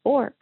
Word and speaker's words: Bork: My last name is Bork, pronounced like cork, and it's French.